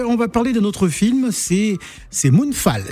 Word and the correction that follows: Moonfall: J'ai bien prononcé. – Non. – Moonfall. – Oui. – En anglais. Moonfall.